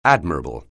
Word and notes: admirable: anglais

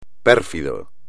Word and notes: pérfido: espagnol

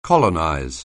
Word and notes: colonize: anglais

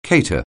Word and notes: cater: anglais

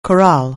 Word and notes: corral: anglais

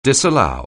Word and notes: disallow: anglais

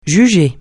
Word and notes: juger: francais

